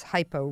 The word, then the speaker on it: hypo: We talked earlier, I explained a little bit about low thyroid function being hypo, and I always remind people that's hypo rhymes with low.